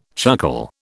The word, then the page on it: chuckle: British English